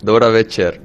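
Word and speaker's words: Dobra večer: Dani Olmo, Dobra Becker, buenas noches. – Buenas noches. ¿Lo he dicho bien? No. – ¿No lo he dicho bien? ¿Cómo es? – Dobra Becher.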